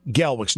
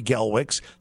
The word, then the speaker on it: Gelwix: And if you, I would like you to sign up, or you sign on as a follower at Coach Gelwicks, not Larry Gelwicks. That's a personal one that for my family, but at Coach Gelwicks. And Gelwicks is spelled G-E-L-W-I-X.